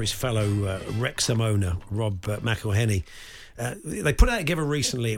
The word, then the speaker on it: McElhenney: It's McElhenney, McElhenney. What ways to massacre and mispronounce it, there are many. It's McElhenney, McElhenney. If there was doubt, now there won't be any. It's McElhenney.